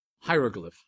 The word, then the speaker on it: Hieroglyph: You mentioned earlier quite a new anthology by edited by Neil Stevenson called, you better pronounce it for me. Hieroglyph. Hieroglyph.